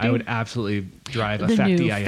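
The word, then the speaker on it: Fakty i Akty: It's Facti I Acti. F-A-K-T-Y-I-A-K. – I like that. – Facti I Acti.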